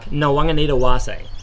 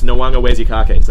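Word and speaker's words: Nawaqanitawase: And Harry Johnson Holmes was telling us earlier how to say it. It's Nawanga Wesikake. That's the rhythm of it anyway.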